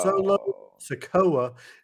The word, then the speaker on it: Solo Sikoa: Matt Riddle versus Solo Sikoa. See, I'm saying it right now. – You are.